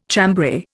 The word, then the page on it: chambray: Indian